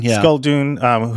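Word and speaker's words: Skulldune: Skuldun. Is that how you pronounce his name? – Yeah, Skuldun, who's a gangster.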